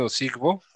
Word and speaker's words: Ozigbo: Divine Osigbo. Se Osigbo. – escribe, se escribe Ozigbo, por si alguien tiene la duda, y está en la necesidad.